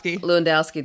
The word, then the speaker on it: Lewandowski: Lewandowski the next day, like plugging them.